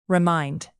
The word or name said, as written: remind